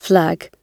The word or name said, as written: flag